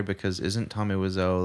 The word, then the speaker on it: Wiseau: I think I've heard it pronounced Wiseau.